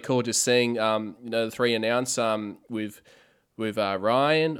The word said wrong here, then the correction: Rian: or I should say it's now pronounced, I think it's pronounced Rian, isn't it? – I believe it's Rian.